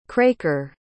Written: craker

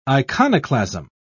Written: iconoclasm

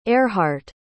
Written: earhart

